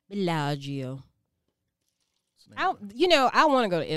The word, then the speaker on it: Bellagio: So, this one, Bellagio. Did I say that one right? – Girl, you trying to get an A plus? You near it? – Yeah. – You said it right. – Okay. That's $12.99. – Say it the Geechee way.